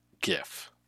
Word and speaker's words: GIF: First of all, it's GIF. It's a hard G.